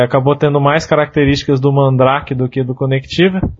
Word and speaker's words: Mandrake: É Mandrake mesmo que pronuncia, Robert? – É, Mandrake.